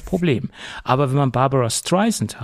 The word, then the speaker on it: Streisand: paar Monaten mit Z ausgesprochen und nicht mit S wie Sand. Äh, das heißt ja Barbara Streisand, der Sand am Strand.